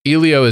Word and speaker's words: Elio: Elio, and it's not Disney Animation.